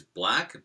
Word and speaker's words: Blaque: Pronouncing correct is Black, but it's BLAQUE.